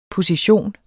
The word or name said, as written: position